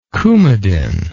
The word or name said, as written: coumadin